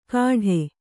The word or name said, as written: ಕಾಢೆ